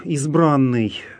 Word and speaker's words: избранный: Псалом «Избранный» в церковнославянском языке, как мы уже говорили в нашей прошлой передаче, это слово произносится именно с ударением на «а», «избранный».